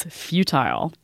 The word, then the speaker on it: futile: But I always think resistance is futile. – And that is one of the two standard pronunciations. The other standard pronunciation is futile. Both fine.